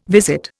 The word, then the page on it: visit: Indian